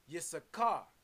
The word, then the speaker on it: Issachar: Issachar. Pronounced Yisachar in the African Hebrew, 3485.